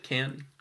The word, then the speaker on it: Cannes: Cannes, yeah.